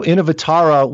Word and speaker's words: Innovatara: And Innovatara, if I'm pronouncing her name correctly. Tell us about those two. – Yeah, you pronounced her name perfectly. And so Innovatara, we wanted someone to represent the idea of innovation.